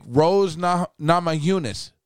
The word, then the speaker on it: Rose Namajunas: Rose Namajunas.